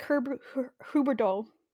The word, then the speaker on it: Huberdeau: Worry, I did take Huberdeau.